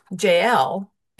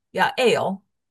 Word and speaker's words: Ya'el: Her name, often pronounced Jael in English, is pronounced Ya'el in Hebrew.